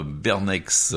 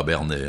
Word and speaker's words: Bernex: Voilà, Starting Block, samedi 2 avril, c'est à Bernex. Et pas à Bernay, hein, parce qu'il y a un Bernay en Suisse. – En Suisse, on prononce pas le X, et en France, on prononce.